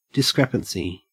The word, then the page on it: discrepancy: Australian English